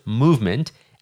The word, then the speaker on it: MVMT: They call that watch brand Movement, spelled M-V-M-T, pronounced Movement, and now they're going to blow it up.